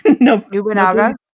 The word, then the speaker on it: Nobunaga: Nobunaga. – Nobunaga.